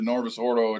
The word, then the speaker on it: Novus Ordo: I've been to Novus Ordo. How do you say that last word? – It's just the N-O, the Novus Ordo.